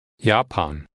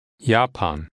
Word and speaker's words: Japan: In German, Japan is pronounced Japan. Japan.